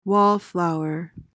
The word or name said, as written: wallflower